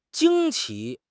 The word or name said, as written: 惊奇